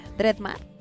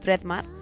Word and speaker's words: Dreadmar: ¿Cómo se pronuncia? Dreadmar. Es como de dreadlooks, ¿no? Como de rastas. Entonces es como Dreadmar.